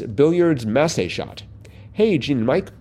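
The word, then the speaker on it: massé: A Massey shot, pronounced Massey, is a shot typically done to curve the cue ball in an arc instead of a straight line.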